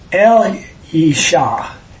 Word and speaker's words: Elisha: It's correct pronunciation, which I will try, but I probably won't because I've said it wrong all my life, is El-Yisha.